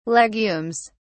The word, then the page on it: legumes: British English